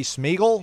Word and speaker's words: Smeagol: Brady Smeagol. Did I say it right? – Yeah, like Lord of the Rings.